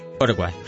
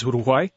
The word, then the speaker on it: Uruguay: So, Uruguay, am I pronouncing that correctly? – Uruguay. – Uruguay, sorry.